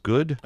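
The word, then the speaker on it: Goode: The Constitution Party's Virgil, and it's not Goody. Is it just Good? – I think it's G-O-O-D-E, Good. – Yes, it is. It's just pronounced Good.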